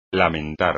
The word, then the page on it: lamentar: espagnol